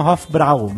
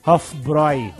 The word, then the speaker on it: Hofbräu: Normalmente as pessoas falam Hofbräu, né? Mas se lê Hofbräu. O A, U, com trema no A, é OI. Mas somos brasileiros, não temos obrigação de pronunciar corretamente tudo fora da nossa língua, tá certo.